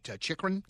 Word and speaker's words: Chychrun: Chychrun? – Yep.